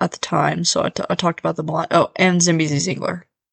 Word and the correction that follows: Zambezi Zinger: I don't think I said that right. Zambezi Zinger. So yeah, a detailed look at those rides.